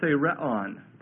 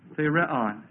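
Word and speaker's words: therion: Now, this word beast is the Greek word thereon, and it means a wild beast. So it's talking about a wild, vicious, marauding, prowling, angry, upset, vicious, greedy, scary beast. That's T-H, long E, R-I-O-N, thereon.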